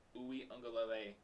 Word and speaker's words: Uiagalelei: How you say that last name? – Uiagalelei. – Is that like the official pronunciation? Yeah.